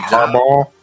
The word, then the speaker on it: Harbaugh: Is it Harbaugh? – Harbaugh. – Don't say it. You see, I think it's that the G and the H that's missing with you. Yeah. Just imagine ball. It's Harbaugh.